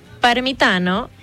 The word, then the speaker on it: Parmitano: Y bueno, te cuento que estos cuatro astronautas son Randy Bresnik, que es comandante de la misión, Luca Parmitano, piloto, Frank Rubio, que es especialista de misión, y André Douglas, que es especialista de emisión. – Parmitano, y que lo pronunciaste muy italiano.